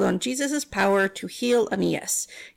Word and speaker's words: Aeneas: So Peter went to the city of Lydda, where there was a man named, I looked this up, it's spelled weird, but it's Aeneas.